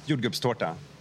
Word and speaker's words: jordgubbstårta: And so the most famous dessert, I think, is called, right, let's see if I can pronounce this, jordgubbstadta. – Yeah, well, it's a strawberry tart. – Is that how do you pronounce it? – Jordgubbstårta. – Okay. – Torta is a tart and jordgubbar is strawberries.